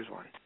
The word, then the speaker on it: Rizwan: But first name is actually Rizwan.